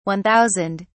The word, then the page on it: 1000: British English